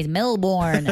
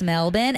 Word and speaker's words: Melbourne: I fucking hate when Americans say Melbourne. I'm like, it's Melbourne.